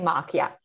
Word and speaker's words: Mock: And so, you know, Winnie's in a rut in some ways, because her boss, Mac or Mock, I don't know how you pronounce it. – Say Mock, I mean, it sounds like, I mean, there's kind of a bit of a sugar daddy connection there.